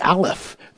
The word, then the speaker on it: Aleph: It's also connected to the Aleph, A-L-E-P-H, pronounced Aleph, is a incredible thing.